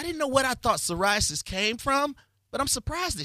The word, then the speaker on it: psoriasis: Psoriasis? – Yes. She has psoriasis, and it's taking over her face.